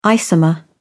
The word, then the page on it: isomer: British English